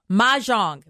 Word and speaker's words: mahjong: Alyssa, first of all, mahjong. Were you saying that correctly? – Yes, mahjong.